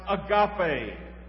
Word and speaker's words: agape: and even changed it to a Greek word in the New Testament that we pronounce agape. Agape.